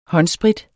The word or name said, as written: håndsprit